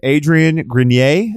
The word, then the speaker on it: Adrien Grenier: Okay, so they've got Eklo Labs, and then they were supposed to do this edutainment web series with Adrien Grenier.